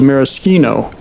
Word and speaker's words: maraschino: I think that the Italians sometimes go with maraschino. – The CH sometimes is a K sound.